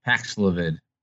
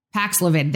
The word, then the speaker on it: Paxlovid: But he takes that jug, that Pfizer drug, which I can never pronounce, Pavloxoid? – Paxlovid. – Paxlovid. There we go.